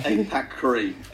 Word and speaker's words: APACRI: APAC. – You're close. – Really? – Yeah. It's APACRI.